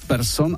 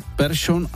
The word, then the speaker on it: Persson: V případě výslovnosti sikavek převážila severská verze změkčení po písmenu R, tedy jména jako Persson a Larsson, teď to říkám, jak jsme to říkávali kdysi, už dnes ve Švédsku nikdo nevyslovuje ani v oficiálním projevu jinak než Persson a Larsson.